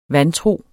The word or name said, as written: vantro